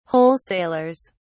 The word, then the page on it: wholesalers: British English